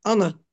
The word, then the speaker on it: honor: We don't honor. – You say honor? – Yes, the H is silent.